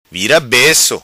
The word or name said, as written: Vira Beco